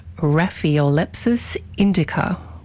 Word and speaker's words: Raphiolepis indica: Ronnie Boy texted and said, my Latin pronunciation of, let me bring it up again, Raphiolepis indica was correct. It sounds kind of more Greek, doesn't it? Raphiolepis.